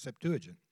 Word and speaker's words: Septuagint: Is that the way it's called? It's pronounced Septuagint.